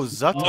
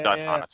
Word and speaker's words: Zatanna: The Tana. – Who? – The Tana animated. The magician girl. – Oh, Zatanna. – Oh, yeah. – Zatanna, sorry.